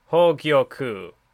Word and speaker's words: Hogyoku: First of all, it's pronounced Hogyoku. So I know you were having some trouble with that before. – Hogyoku. Well, because it's like, I keep wanting to say Gyoku, but it's Gyoku, I think, right?